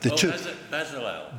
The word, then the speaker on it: Bezalel: And then he says, P.S., I do hope you're hanging about with the chap you've just mentioned, B, I can never pronounce the name, actually, Beelze and Holiba. – Bezalel. – That's it, yeah.